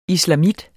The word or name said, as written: islamit